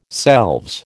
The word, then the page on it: selves: American English